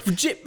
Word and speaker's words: GIF: It's a hard G. You can't go from a hard G to a soft G. – It's a clunky word. – GIF, what do you mean, mate?